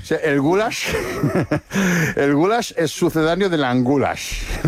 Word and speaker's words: goulash: Es que ni siquiera es una carne, el goulash es pronunciado así, goulash.